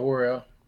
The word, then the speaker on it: Worrell: but Ron DeSantis suspended Orlando State Attorney Monique Worrell. I don't know how to say her last name, but yeah, Worrell.